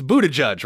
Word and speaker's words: Buttigieg: First of all, I'm saying it correctly, right? It's Buttigieg, right? – Yeah, you got it.